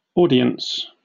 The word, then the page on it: audience: British English